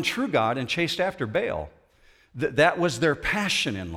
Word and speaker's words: Baal: Now, Baal is Baal, if you want to pronounce it the correct way, in the Old Testament.